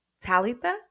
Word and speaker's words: Talitha: And just real quick, I'll tell you that um, it's actually pronounced Talitha in the Greek.